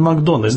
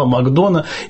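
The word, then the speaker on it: McDonagh: Но я настаиваю, что произносить его имя нужно Мартин Макдональд. Знаете почему? – Именно так, конечно, это ирландская фамилия. – Я уточнил у своей парикмахерши, которая ирландка из Ирландии, настоящая. Я ее спросил, как правильно произносить. Она сказала Макдона и посмотрела на таким знаменитым ирландским взглядом. – Все, будем говорить Макдона.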